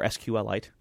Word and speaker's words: SQLite: Direct SQLite calls, or SQLite, excuse me. That's the actual way to pronounce that.